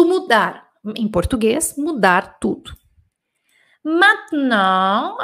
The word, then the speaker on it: maintenant: Ma, essa é uma palavra legal também de trabalhar a pronúncia, porque aqui você tem o man, igual mão, né? Man. Aqui você tem o e mudo, mat. E aqui você tem o nasal on, Maintenant. Você tem duas nasais aqui, o man e o non.